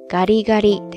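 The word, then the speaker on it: カリカリ: 这个时候的声调呢，就是刚才读的咖喱咖喱，ですね。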